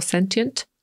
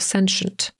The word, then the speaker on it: sentient: To słowo można wypowiedzieć na dwa sposoby, albo sentient, albo sentient. I po brytyjsku, i po amerykańsku obie te wersje są ok, więc możecie się spotkać i z tą, i z tą. Sentient albo sentient. Pisze się dokładnie tak samo.